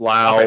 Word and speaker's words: Lowe: One's pronounced Lau. Okay. One, like, insane how all these guys have the same last name and it's like all these different pronunciations and it's only four letters.